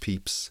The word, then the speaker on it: Pepys: But to talk about early appearances of the song, the first recorded mention of it is in a diary entry by Samuel Pepys, not Samuel Pepys like I called him before. I since found out it's pronounced Pepys.